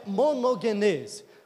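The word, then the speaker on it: monogenes (μονογενής): Mas a pronúncia é monogenes.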